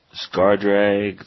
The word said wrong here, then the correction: Skirzdag: High Priest. It's a creature, human cleric. – Skirzdag. – Skirzdag. There you go. Skirzdag High Priest.